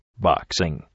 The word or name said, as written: boxing